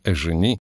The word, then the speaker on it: Эжени: Разумеется, в слове «Эжени» ударение надо ставить по-французски на последний слог.